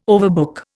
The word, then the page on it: overbook: Indian